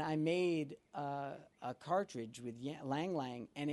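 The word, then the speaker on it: ylang-ylang: Is it Lang Lang? – Oh, yeah. I've always said Lang Lang. – Okay, Lang Lang.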